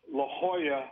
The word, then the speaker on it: La Jolla: We were going into L.A., Los Angeles, and there's an arrival called the La Jolla arrival. But when you look at it, it's spelled with a J. It's spelled J-O-L-L-A.